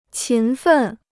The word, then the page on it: 勤奋: qín fèn